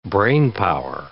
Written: brainpower

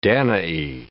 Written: Danae

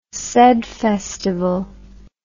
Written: sed festival

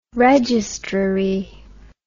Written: registrary

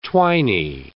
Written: twiny

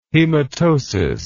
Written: hematosis

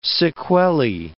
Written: sequelae

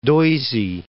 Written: Doisy

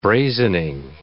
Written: brazening

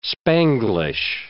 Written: Spanglish

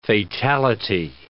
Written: fatality

